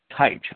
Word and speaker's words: Tajc: Tajc.